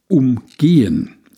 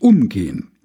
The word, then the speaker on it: umgehen: Es gibt das Wort umgehen, erklärte er und betonte es auf der zweiten Silbe. Also einen Stau oder eine Baustelle umgehen. Und es gibt umgehen. Betonung auf der ersten Silbe. Freundlich miteinander umgehen.